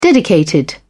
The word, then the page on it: dedicated: British English